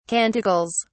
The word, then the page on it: canticles: British English